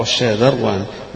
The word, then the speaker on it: الشاذروان: أما الشاذروان قلنا أنه هذا النطق بتاعه الشاذروان بشين معجمة وذال معجمة مفتوحة ثم راء ساكنة،